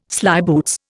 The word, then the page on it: slyboots: Indian